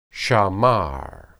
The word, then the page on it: shamar: hebrew